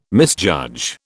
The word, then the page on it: misjudge: British English